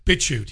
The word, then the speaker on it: BitChute: BitChute, yeah, that's how you pronounce it.